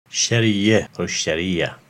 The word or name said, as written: Shariah